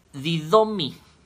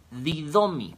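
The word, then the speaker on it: didomi (δίδωμι): La raíz de dosis, esta palabra que se usó para dádiva, que significa regalo, es didomi, didomi, con acento en la i, con, en la primera i, didomi.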